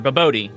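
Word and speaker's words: bobotie: And so for this dish, we did one of South Africa's very famous dishes called babuti. It's babuti's or babodi. Babodi is how it's pronounced. Yeah, babodi.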